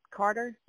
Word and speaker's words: Carter: Carter keeps you, and I'm saying her name right, Carter? – Yep, Carter, like the president.